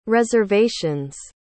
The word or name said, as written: reservation's